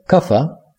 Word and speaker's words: Kapha: Üçüncü ve son olana kafaya gelince, kafa, K-A-P-H-A olarak yazılıyor, kafa diye okunuyor, dünya ve su elementlerinden oluşur ve yapışan olarak tercüme edilir.